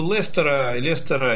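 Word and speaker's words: Leicester: Вы знаете, да, как Лестер пишется по-английски? Он пишется Лестер. И когда... – по-французски. – Да, и когда А там это Сей, там много из городов у них. Лестер, Бистер, Глостер, там не только Лестер. Также пишется и тоже пропускает середина.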